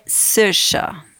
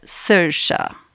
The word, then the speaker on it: Saoirse: Por isso que é S-A-O-I-R-S-E é Sirsha, Se pronuncia Sirsha.